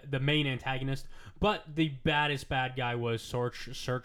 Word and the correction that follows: Surtur: Surtur, who essentially made Ragnarok happen.